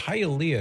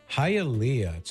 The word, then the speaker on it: Hialeah: Hialeah, am I saying that correctly? I think yes, you are. You got it. – Hialeah. So if you know a girl named Leah, you'd say Hialeah.